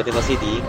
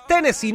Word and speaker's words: Tennessee: Oye, yo pensé que todo pasaba en Talca, pero sorprendió Tennessee. – Tennessee. No, no, no, es Tennessee, no Tennessee.